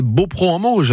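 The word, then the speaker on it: Beaupréau-en-Mauges: Alors j'espère que je le prononce bien, Chantal. Beaupro en Mauge, hein, on est d'accord? – Oui.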